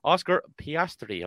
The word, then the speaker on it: Oscar Piastri: Oscar Piastri, am I getting that right? – That's it, yeah.